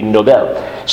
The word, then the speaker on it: Nobel: In English, we would pronounce it Nobel, I guess, but in Swedish, it would be Nobel.